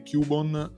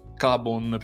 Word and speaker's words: Cubone: Vabbè, in realtà eh, partiamo dalle cose importanti, smontiamo mezz'ora di puntata dicendo che Cubon non si dovrebbe pronunciare Cubon, ma Cabon, perché comunque è l'unione di Cab, che è cucciolo, e Bon, che è osso.